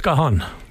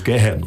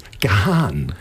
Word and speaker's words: Gahan: Und wie spricht man den Namen des Sängers richtig aus, den kompletten Namen? – Dave Gahan. – Gahan. Ja, das ist, danke. Siehst du, ich sage immer Dave Gahan oder sowas. Gahan.